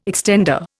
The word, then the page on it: extender: Indian